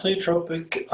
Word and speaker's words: pleiotropic: Yeah, pleiotropic is a term that we use to cover this idea that NRF2 turns on numerous classes of genes.